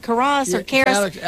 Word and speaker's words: Karras: Yeah, I didn't know if I said it right. Karras. – Alex Karras. He did play for the Detroit Lions.